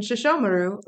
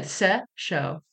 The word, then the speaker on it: Sesshomaru: You told me that was how to say it. Sesshomaru. – Yeah. – Accent on the show. You said Sesshomaru. – I was just giving you the syllables. Sesshomaru. – That's a very different... That was the key that I was missing. I was like, I know there's a da-da-da-da, but I couldn't figure out where to put the thing.